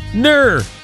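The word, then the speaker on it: gnurr: And that term is NURR, with the silent G, G-N-U-R-R, NURR.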